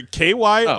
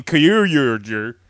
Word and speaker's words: Kyoryuger: Yeah, I got it right here. Kyoryuger. – Yeah, there you go. Yeah. The other R-Y in there is very important.